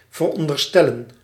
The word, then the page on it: estimer: France; [ɛs.ti.me]